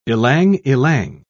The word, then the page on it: ilang-ilang: British English